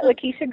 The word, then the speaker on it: Lakisha: Yeah, Lakisha Greenwade.